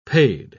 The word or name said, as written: paid